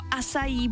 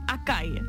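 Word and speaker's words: açaí: Asaj nebo asají bowl a ne akaj. Někdo říká acai bowl, tak je to acai, acai.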